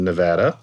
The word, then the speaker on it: Nevada: And I was very happy to hear last night what happened in Nevada. It's the first time I've ever said it right. Not Nevada, Nevada.